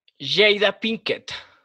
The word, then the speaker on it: Jada Pinkett: Jada Pinkett.